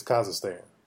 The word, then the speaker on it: Kazakhstan: K-A-Z-A-K-H-S-T-A-N. I think it's Kazakhstan.